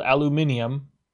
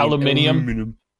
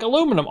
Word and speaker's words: aluminium: But it's also like, well, because I'm on Google right now, and it's like also spelled aluminium. – Oh, there is a second I in fucking hell, you got me saying aluminium. – Aluminium. In the British word. – That's... – Has the extra syllable.